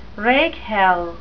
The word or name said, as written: rakehell